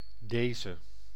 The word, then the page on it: this: /ðɪs/